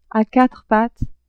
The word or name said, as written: a quatre pattes